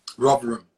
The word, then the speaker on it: Rotherham: And then eventually you played for, you were a central defender for Rotherham, is that how you say it? – Rotherham.